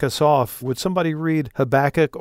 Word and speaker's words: Habakkuk: And the reason I wanted to get us back into that groove a little bit is because this time we come to Habakkuk. – Mm-hmm. I was in the UK once and I heard a pastor pronounce it Habakkuk.